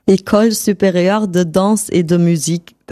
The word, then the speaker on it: École supérieure de danse et de musique: Wie wird das richtig ausgesprochen? – École supérieure de danse et de musique.